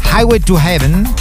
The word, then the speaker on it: Highway to Heaven: Highway to Heaven con Michael Landon.